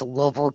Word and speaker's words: Louisville: Uh, yes, it's Louisville, Kentucky.